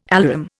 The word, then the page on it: alarum: Indian